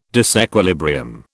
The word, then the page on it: disequilibrium: British English